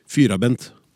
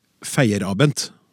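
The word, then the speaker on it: Feierabend: Fyrabend. Uttalelsen her er sveitsertysk, det er ikke uttalelsen min, trodde jeg, men ok. Fyrabend. På høytysk ville det vært feierabent, noe som betyr at arbeidsdagen er slutt.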